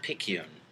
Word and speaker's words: Picayune: Our special guest this week is Habib Kurdi, the sports editor of the Lake Travis View and the Westlake Picayune. Did I say that right? – You got it.